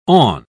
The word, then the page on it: awn: British English